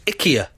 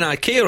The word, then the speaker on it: IKEA: Apparently it's pronounced IKEA. I've been saying IKEA all this time.